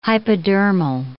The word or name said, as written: hypodermal